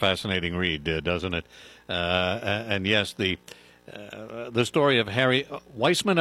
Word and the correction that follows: Greissman: am I pronouncing that correctly? – No, it's Greissman.